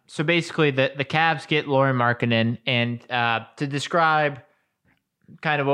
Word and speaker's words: Lauri Markkanen: Lauri Markkanen. Am I saying that correctly? – Lauri Markkanen.